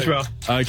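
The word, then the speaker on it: Konnichiwa (こんにちは): Konnichiwa. – Correct. – Konnichiwa.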